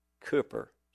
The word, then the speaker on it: Cowper: And so it's, remember, Cooper, spelled Cowper, pronounced Cooper.